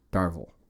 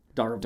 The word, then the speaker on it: Darvill: Arthur Darvill. – Darvill. – Darvill. – I see Darvill, and I just want to say Darvill. – He's English. – It's not Cruella Darvill.